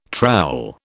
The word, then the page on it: trowel: British English